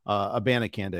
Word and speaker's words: Abanikanda: Michael Carter, Abakanda, I think, has got some juice to him. Abanikanda, excuse me. I always get that. I always mispronounce his name.